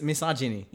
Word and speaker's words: misogyny: Well, how do you pronounce that word? Is it miso miso what? – Mis- misogyny.